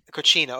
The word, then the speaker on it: cocina: Cocina. – Yeah, Cocina.